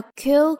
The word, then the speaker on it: cue: 那演员们看到 cue 就知道轮到自己上场了。 cue card，cue card，提词板。字母 q 和 q 这个单词啊，它的发音是一样的。